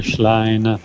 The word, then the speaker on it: Schlein: cioè non si può sentire, caro ascoltatore, Schlein, non è difficilissimo, anche se sì, non sappiamo il tedesco.